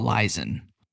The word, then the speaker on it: Leisen: It is Mitchell Leeson. – Mitchell Leeson. Yep. – Which actually has a way better ring to it than Leeson.